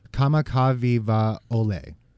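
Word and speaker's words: Kamakaviva-Ole (Kamakawiwoʻole): Israel Kamakaviva-Ole.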